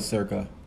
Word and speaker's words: circa: King S reigned from 1113 to, how do you say that? C 1150? What is that? Is that Christ? – It's probably circa. – Oh, okay. Wow. To circa 1150.